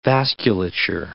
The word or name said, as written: vasculature